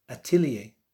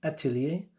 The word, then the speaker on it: atelier: And this is the word that in French they say is atelier. Once again, atelier.